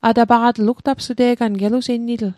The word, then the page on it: atapaqatl: Mi'gmaq